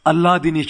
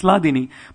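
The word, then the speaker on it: Hladini: A lot of times devotees pronounce it as Alladini Shakti. It's Ladini, but devotees pronounce it as Alladini Shakti.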